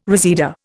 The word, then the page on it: reseda: Indian